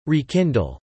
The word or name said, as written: rekindle